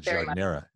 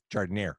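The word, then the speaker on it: giardiniera: It becomes jardinera in Chicago. It's not jardinera, it's jardinera.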